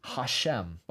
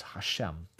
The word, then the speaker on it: Hashem: The name Hashem, by the way, is how you pronounce it in Hebrew. It's Hashem, the name Hashem.